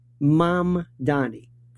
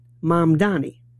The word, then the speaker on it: Mamdani: Mamdani. First point is, it's a very simple thing. Three syllables. Mom, like mom and dad. Donnie, like Donny Osmond. Mamdani.